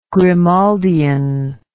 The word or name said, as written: Grimaldian